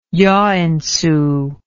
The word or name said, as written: joensuu